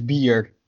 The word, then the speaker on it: bier: But yeah, no, I can come up with some good Dutch words, but the word for beer is bier, so it's pretty the same as English. – You just kind of pronounce that ear a little bit more.